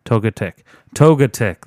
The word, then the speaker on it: Togetic: Togetic.